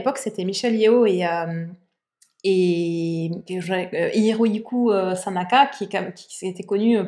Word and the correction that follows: Sanada: C'est pas Sanada Sanada, pardon.